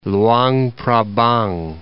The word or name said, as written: Louangphrabang